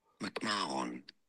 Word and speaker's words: McMahon: In standard English, British English, it's said as McMahon. – McMahon. – McMahon.